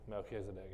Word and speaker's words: Melchizedek: The Greek version is Melchizedek, not so much for the Hebrew, but the Greek, absolutely, Melchizedek. That's where we get our pronunciation from.